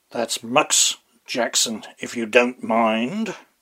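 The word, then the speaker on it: Mx: M.X. Pronounced Mux.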